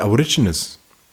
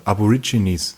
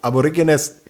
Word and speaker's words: Aborigines: weil die Aborigines, auch falsch ausgesprochen, Aborigines. – Weiß ich gar nicht, ja? – Aborigines.